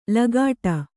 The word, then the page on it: ಲಗಾಟ: lagāṭa